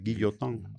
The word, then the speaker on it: Guillotin: Qué forma Se pronuncia, tan... tú que hablas francés perfectamente, es Guillotin.